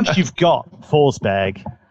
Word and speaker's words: Forsberg: How do you say that name? – Forsberry, so, according to UEFA. – Yeah, this is one of those, right? – It's more like Forsberia, isn't it?